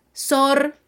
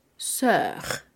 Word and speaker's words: sœur: Cuidado en esta pronunciación, ya no diremos más sor si vemos esta palabra en francés, sino diremos sœur. Sœur.